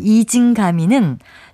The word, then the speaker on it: 이징가미: 발음 그대로 쓰는 이징가미는 질그릇에 깨어진 조각을 뜻하는 우리 고유어 표현이고요.